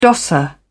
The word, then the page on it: dosser: British English